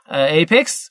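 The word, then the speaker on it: Apex: اپکس منتشر شد که تو دو هفته اول بله بله با تلفظ درست میشنیم از استاد ایپکس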